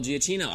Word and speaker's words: Giacchino: Michael oh, um, Michael Giacchino.